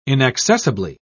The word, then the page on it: inaccessibly: British English